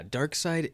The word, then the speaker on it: Darkseid: Anyway, this minute of the movie, yeah, Darkseid is a very formidable. – Yeah, you're saying it correctly. You said it correctly.